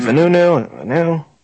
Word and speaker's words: Vanunu: But nonetheless, the book, which I have not gotten a chance to read, talks about Mordechai Vanunu, or however you pronounce his name. Is it Vanunu? – Yes.